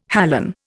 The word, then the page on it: halon: Indian